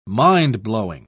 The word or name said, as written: mind-blowing